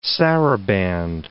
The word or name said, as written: saraband